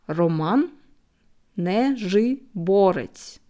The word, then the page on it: Roman Nazhyborets: slow